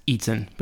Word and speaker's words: Eten: The restaurant is a waterfront vegan bar called Mediamatic, and we think it's pronounced Eaton, but it's spelt E-T-E-N.